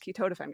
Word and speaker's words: ketotifen: It's kind of like my patient saying ketotifen. There's an antihistamine drug called ketotifen, and every patient says ketotifen or I can't even pronounce it wrong, or ketotifen, and they just like mumble it. I'm like, it's ketotifen, guys, ketotifen.